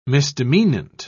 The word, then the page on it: misdemeanant: British English